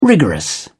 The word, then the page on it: rigorous: British English